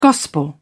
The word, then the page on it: gospel: British English